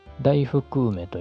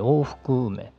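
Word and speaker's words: 大服梅: これ往復梅ってね、大きな服の梅って書くんですけれども、大福梅と読むんじゃなくて往復梅って読むみたいですね。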